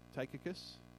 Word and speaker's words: Tychicus: And maybe that's not how you pronounce his name, because how do you pronounce T-Y-C-H-I-C-U-S? Tychicus? We're going to say Tychicus.